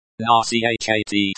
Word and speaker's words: Начать: Nachat, stress on the second syllable.